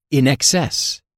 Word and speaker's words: INXS: Aunque son solo siglas, si pronuncias el nombre de este grupo en inglés, dirías INXS, en exceso, ya que se pronuncia INXS.